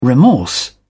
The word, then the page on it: remorse: British English